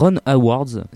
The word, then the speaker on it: Ron Howard: On passe tout de suite à Solo, Star Wars Story, réalisé par Ron Howard, si je le prononce bien.